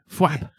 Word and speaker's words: fwap: No, I'm writing down glad thwap because I think it's a good title for the episode. – Oh, look at him. – Glad thwap. – No, fwap. Fwap. – Fwap. – So it's spelled with an F. – F-W-A-P.